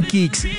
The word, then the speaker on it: Kixx: Protege tu moto con Kicks, K-I-X-X. Aceites sintéticos y semisintéticos para un máximo rendimiento. Síguenos en Instagram, como arroba aceite-kicks. Diego, ¿cómo se escribe Kicks? Ya le digo, señor, se escribe K-I-X-X.